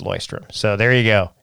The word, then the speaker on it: Leuchtturm: By the way, I learned that it's pronounced Loistrum.